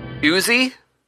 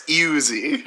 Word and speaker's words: Ewzzy: The next category comes from Uzi? Iwizi? I'm not sure how you pronounce it. – I think it's Uzi. – Uzi, but it's not David Uzumeri. It's spelled E-W-Z-Z-Y.